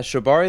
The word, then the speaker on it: shibari: Did you participate in shibari? – Shibari.